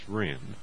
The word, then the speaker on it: Rehn: It's Rehn, R-E-H-N.